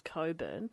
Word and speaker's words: Cockburn: There's a place in Western Australia that's called, it's spelt Cockburn, but it's pronounced Coburn.